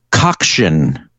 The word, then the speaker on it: coxswain: C-O-X-S-W-A-I-N. I pronounced it coxswain when I did this at trivia. And Steve, you've had people like this at your games. – Yeah, I had a guy walk all the way from the other room to come up to tell me that I had mispronounced it.